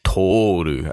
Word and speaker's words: Toru: Toru, I should say. – Toru, okay. – Yeah, long O.